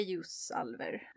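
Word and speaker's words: Ljósálfar: Leosalfar. Leosalfar. Ja, man ska uttala det ljusalver, mm. Eh, men stavningen ser ut som det gör i hans böcker.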